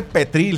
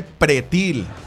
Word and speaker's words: pretil: Pues es que no se dice pretil, dice, no se dice petril, se dice pretil.